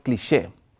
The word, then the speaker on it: cliché: Okay, first, film noir, existentialism, and the idea of cliché. It's in, in, in English, you also pronounce it on, uh, as French, right? Cliché? Mm-hmm.